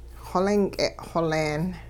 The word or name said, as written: xolink'e xoleen